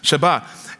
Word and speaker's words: Shabbat: You know, the word Sabbath, it comes from this word Shabbat. Shabbat, if you're a bit more pronouncing properly. Shabbat.